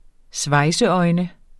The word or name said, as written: svejseøjne